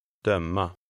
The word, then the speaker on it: döma: 8. The stress is on the second syllable. – Döma.